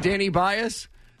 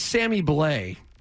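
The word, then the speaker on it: Sammy Blais: Danny Bias, that's Sammy Blay. – Mm-hmm. – Right. Gentlemen not knowing how to pronounce things. The French, B-L-A-I-S. Sammy Blay. – Sammy Blay? – Not Danny Bias.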